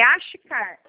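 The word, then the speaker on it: Achcar: É Achicar. A-C-H. – A-C-H. – C-A-R. C-H é mudo. Achicar. – A-C-H-C-A-S? – A-R. C-A-R.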